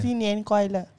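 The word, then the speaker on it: Xin Yin Kuai Le (新年快乐): Xin Yi Kuai Le. Eh, apa eh? How do you pronounce it correctly? – Xin Yin Kuai Le.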